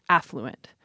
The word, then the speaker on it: affluent: There, they say, historically, the pronunciation with the stress on the second syllable has been criticized, that the idea is the stress should come on the first syllable, affluent.